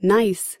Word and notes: nice: American English

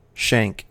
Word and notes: shank: American English

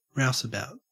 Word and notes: rouseabout: Australian English

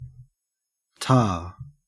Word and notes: tar: Australian English

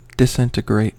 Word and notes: disintegrate: American English

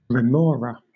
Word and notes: remora: British English

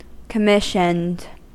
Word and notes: commissioned: American English

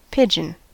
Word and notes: pigeon: American English

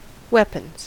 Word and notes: weapons: American English

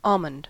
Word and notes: almond: American English

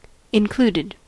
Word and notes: included: American English

